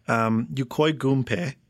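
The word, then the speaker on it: Yokoi Gunpei: And all of these business ventures failed, and Nintendo stock plummeted until there was a guy, Yukoi Gunpei.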